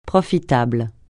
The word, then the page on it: profitable: francais